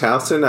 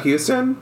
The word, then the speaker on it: Houston: Just like Houston Street is pronounced Houston, not Houston.